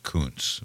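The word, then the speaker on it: Kunce: And he ended up in a debate with Ben Domenech, who said I think it's pronounced Kuntz. – Kuntz. Yeah. Did I say Kuntz? Yeah. Oh, shit. – You did. – Yeah, okay. Well, that's probably the wrong pronunciation. So you're exactly right, and I thank you for that correction. So in reaction to Kuntz. – Yes. – That's K-U-N-C-E is the guy's last name.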